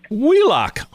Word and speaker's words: Wheelock: Wheelock.